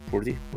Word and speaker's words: Purdy: Así se pronuncia, se pronuncia. Purdy, Purdy.